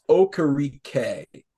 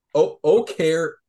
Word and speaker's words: Okereke: Bobby it's not Okereke. By the way, Okereke, right? – I don't care. Ryan Dizdier just subtweeted us, by the way, on the Bobby O pronunciation. It's Okereke. Okereke.